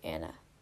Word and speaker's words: Anna: My first name is pronounced Anna, just like the name Anna.